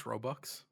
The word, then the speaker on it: Robux: Roblox bucks. That's what he got. That was what he wanted. – They're pronounced Robux.